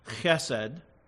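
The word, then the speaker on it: chesed (חסד): It's a single word in the Hebrew pronounced chesed, and it's variously translated in your English Bibles, depending on what translation, loving kindness, tender mercy, covenant love, or steadfast love.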